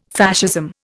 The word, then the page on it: Fascism: Indian